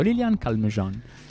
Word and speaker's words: Lilian Calmejane: Scusa, vorrei che tu lo pronunciassi come si conviene nel frentaccio. Lilian Calmejean. – Sì, no, l'ho detto bene, va bene come l'ho detto. Lilian Calmejean, che comunque parla un ottimo inglese, per cui ci sorprende così.